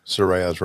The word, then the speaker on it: Saraya: I think we've all been saying it incorrectly, not Saraya, but Saraya. – Right. Saraya's right.